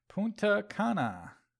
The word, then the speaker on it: Punta Cana: In other news, the new location for Capcom Cup 2020 has been announced, as it is now headed to Punta Cana. I hope I said that right. Dominican Republic. – Yeah, I think you're Punta Cana. Yeah, you're fine.